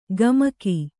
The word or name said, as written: ಗಮಕಿ